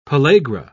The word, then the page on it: pellagra: British English